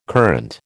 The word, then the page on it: courante: American English